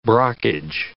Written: brockage

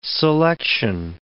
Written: selection

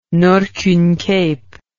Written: Nordkyn Cape

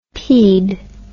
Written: peed

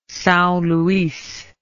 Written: Sao Luis

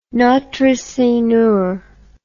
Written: Notre Seigneur

